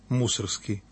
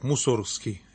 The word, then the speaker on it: Мусоргский: Это Модест Петрович Мусоргский. Ударение ставлю на «у». Сейчас, знаете, модно говорить так, Мусоргский. Это значит, что вы больше знаете, больше понимаете, это значит, что это правильно. Это не совсем так.